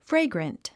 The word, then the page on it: fragrant: fréiɡrənt; フレイグラント